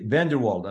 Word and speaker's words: VanderWalde: Thank you so much for coming on the Caris Molecular Minute, Dr. Ari VanderWalde. And I did say your last name correctly. – You did, and I appreciate that.